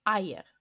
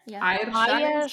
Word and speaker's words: Eier: It's Eier Schadel, so Bruchstellen Virur Sacher. – Eier.